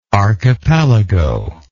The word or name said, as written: archipelagian